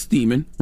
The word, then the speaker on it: Steeman: This dude, Thomas Steeman, rhymes with semen, was going around with syringes full of piping hot semen and stabbing random women with it?